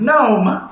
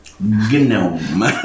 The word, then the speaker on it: gnome: K-N-O-M-G. – It's an N word, but it doesn't have a letter at the beginning. It's got a silent letter at the beginning. Is it a K? It's usually a K, right? – K-G-N-O. – Knife. Gnome. Why is it a G? – Right.